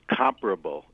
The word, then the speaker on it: comparable: The word is comparable, accent on the first syllable.